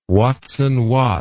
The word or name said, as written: watson watt